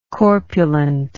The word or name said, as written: corpulently